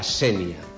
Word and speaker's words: Sénia: Val a dir que quan ens referim al topònim hauríem d'utilitzar la fonètica tancada de la E, La Sénia, en E tancada, tot i que quan ens referim a la paraula, al mot, al nom, al substantiu, La Sénia, amb el significat de sínia, també està acceptada la pronúncia oberta, La Sénia, La Sénia. Però quan ens referim al topònim sempre hauríem de respectar la pronúncia local, La Sénia, tancada.